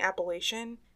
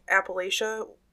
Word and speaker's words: Appalachia: So, because I'm from Pennsylvania, I was raised pronouncing everything Appalachian and Appalachia. And that is the most common way to say it for the northern half of the mountain region. But I know the southern and, like, central southern half pronounces the whole region as Appalachia, especially in the majority of the states these tales are in. So I'm probably going to end up using them interchangeably. It's all regional dialect.